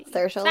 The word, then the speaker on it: Saoirse: Saoirse like inertia. – Oh, I think I got it. Who knows? Trash me in the comments below. – Saoirse like inertia.